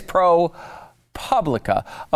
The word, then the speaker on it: ProPublica: Ah, okay, so it is ProPublica.